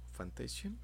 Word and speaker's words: Fantasian: Fantasian. Fantasian, ¿Sí se pronuncia así, sí.